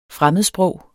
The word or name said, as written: fremmedsprog